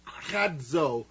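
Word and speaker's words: kradzo (κράζω): But the word here is kradzo. You would pronounce it K-R-A-D-Z-O.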